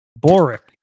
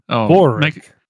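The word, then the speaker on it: börek: Boric. Probably supposed to roll the R. – What? Oh. – Boric.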